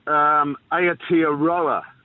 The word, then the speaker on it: Aotearoa: Aotearoa.